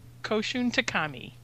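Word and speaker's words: Koushun Takami: And today we will be discussing Battle Royale by Koshin Takami.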